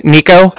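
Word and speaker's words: Niko: It's Nico. That's N-I-K-O. And then Cardiff is spelled C-A-R-D-I-F-F, as in Frank.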